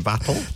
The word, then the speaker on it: Vettel: Sebastian Vettel? – That's actually how you pronounce his name. – Yeah, that's how he says it.